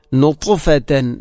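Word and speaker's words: നുത്ഫത്തൻ (نطفة): നുത്ഫത്തൻ, ത ഇൻ്റെ ഉച്ചാരണം, ത ഇൻ്റെ മഖ്റജ് ഒരിക്കലും തെറ്റാൻ പാടില്ല, ത അല്ല പറയേണ്ടത്. ഇവിടെ താഉം ഫാഉം മഖ്റജ് ഒന്നാണെങ്കിലും ത ഉച്ചരിക്കുമ്പോൾ അതിൻ്റെ സ്വിഫത്ത് അഥവാ നാവ്, നാവിൻ്റെ നടുഭാഗം മേലണ്ണാക്കിലോട്ട് ചേർന്ന് വരിക എന്ന ആ ഒരു സ്വിഫത്ത് ശരിയായിട്ടില്ല എങ്കിൽ രണ്ടും ത ആയി മാറും. അപ്പോൾ നുത്ഫത്തൻ, നുത്ഫ, നുത്ഫത്തൻ ഇങ്ങനെ തന്നെ ഉച്ചരിക്കണം.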